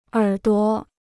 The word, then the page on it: 耳朵: ěr duo